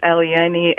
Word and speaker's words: Eliane: Her name on my prep sheet here spells Elaine, but it's actually Eliane. Is that right? – Hi, Tyler. That's right. My name is Eliane, and thanks for having me here today.